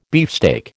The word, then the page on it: beefsteak: American English